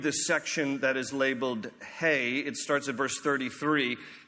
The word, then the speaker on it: He: Now, it's the Hebrew letter is just spelled H-E. So in English, we would look at that and say it's He, but it's pronounced Hey.